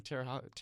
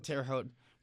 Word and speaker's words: Terre Haute: in, I always pronounce this wrong, Terre Haute? – Yeah. – Okay, so we've got Terre Haute Wednesday, 4 p.m.